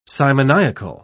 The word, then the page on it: simoniacal: British English